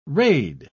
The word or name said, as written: raid